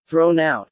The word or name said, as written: thrown out